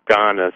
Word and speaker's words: Ganesh: Yeah, so that was just a little climb that Ganes, it's spelled Ganesh, but over there it's pronounced Ganes.